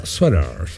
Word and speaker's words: sweater: Ya, sweater. Ah, sweater. Se pronuncia sweater.